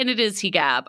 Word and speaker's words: hijab: We should point out, too, Fatima mentions her hijab. Hijab is apparently the correct pronunciation in Egyptian Arabic, so.